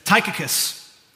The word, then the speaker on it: Tychicus: Tychicus, I know that one's pronounced properly. Tychicus, our dearly loved brother, faithful minister, and fellow servant in the Lord, will tell you all the news about me.